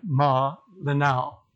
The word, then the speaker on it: Malinao: I have to get the syllables right. Malinao.